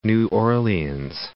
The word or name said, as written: New Orleans